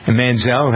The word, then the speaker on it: Manziel: Manziel.